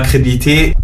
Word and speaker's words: crédibilité: Financièrement parlant, je n'avais aussi aucun intérêt, j'ai perdu des partenariats, j'ai perdu ma crédibilité. – Ta crédibilité. – Ouais. – Non, alors tu as oublié une syllabe, tu as oublié le lit. – Crédibilité. – Débilité, c'est comme débilité.